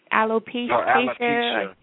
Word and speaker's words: alopecia: I think it is alopecia or alopecia.